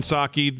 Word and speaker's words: Psaki: Jen Psaki, the P is silent, in the briefing room.